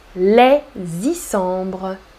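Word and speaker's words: Les Issambres: Tu fais la liaison entre les et Isambres les Et tu ne prononces pas le S final.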